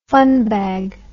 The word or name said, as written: funbag